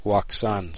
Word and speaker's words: Wouxun: Although osheng.us, which is really pronounced very differently from what it's spelled, so I'm going to spell this out for you here and we'll just stop reading for a second. osheng.us is actually spelled wouxun.us, and that's why I've been pronouncing it Wuxun, so that it's not so confusing.